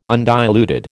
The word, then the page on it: undiluted: American English